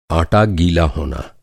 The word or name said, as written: आटा